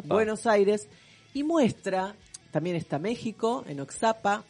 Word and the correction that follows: Oaxaca: Oxapa se dice... Oaxaca. Acá dice O-A-X-A-C-A, Oaxaca, si se dice así. Oaxaca se dice.